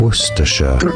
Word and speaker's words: Worcestershire: Worcestershire. Stress on the first syllable. Worcestershire. Yeah. Only 3 syllable.